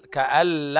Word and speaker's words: كأن: طيب، كأن لم، النون لا تنطق، كأن لم يسمعها.